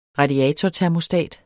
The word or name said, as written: radiatortermostat